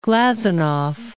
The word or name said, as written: Glazunov